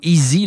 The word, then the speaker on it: Eayz: Et c'est ce qu'a fait EZ, donc, ou Easy, là, ça s'écrit E-A-Y-Z.